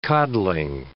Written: codling

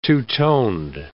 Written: two-toned

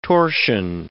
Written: torsion

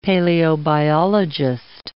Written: paleobiologist

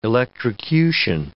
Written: electrocution

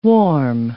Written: warm

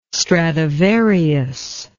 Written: stradivarius

